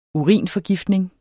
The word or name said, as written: urinforgiftning